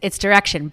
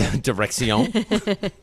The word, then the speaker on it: Direxion: Direxion, what am I saying it right? – It's Direxion, but a lot of people like to say it the French way, so you're all good. – Direxion.